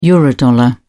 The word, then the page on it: eurodollar: British English